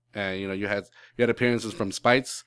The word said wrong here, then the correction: Speights: from Speights. I'm sorry, I keep, keep mispronouncing his name.